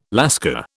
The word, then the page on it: Lascar: British English